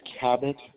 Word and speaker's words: Cabot: Yes, my first name is Cabot. It's a little unusual. It's spelled C-A-B-O-T.